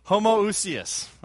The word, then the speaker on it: homoousios: Athanasius refused unless Arius would affirm the, how do you pronounce it? – Homoousius. – All right. He says it so much better than I do.